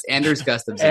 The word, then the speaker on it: Anders Gustafson: Yes, Anders Gustafson.